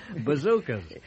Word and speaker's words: bazookas: Getting the hang of these new, bazookies, they started coming. – Bazookas. – Well, is that how you say it? – Yeah.